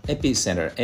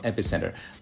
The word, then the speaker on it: epicenter: で、発音はですね、あの、エピセンター、 A が強いんですけど、エピセンター。